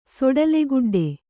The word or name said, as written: ಸೊಡಲೆಗುಡ್ಡೆ